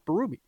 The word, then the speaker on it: Berube: No, it's not Burby. It's Berube.